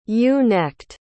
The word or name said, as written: ewe-necked